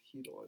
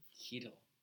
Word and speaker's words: Chytil: It's pronounced Heedle, I believe. – Heedle.